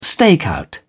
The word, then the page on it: stakeout: British English